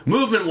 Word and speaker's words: MVMT: Movement watches pronounced very coolly. Very few vowels used in that.